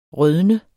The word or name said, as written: rødne